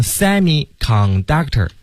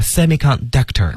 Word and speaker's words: semiconductor: 对，那这个词呢，大家不要读成 semiconductor 啊，它的那个重音呢是放到后面，所以是 semiconductor。